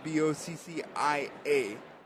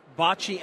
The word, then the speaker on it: boccia: Okay, but bocce and boccia, right? No, nope. so s- we still pronounce it bocce, but without the A. Oh, it's there, but we don't pronounce it.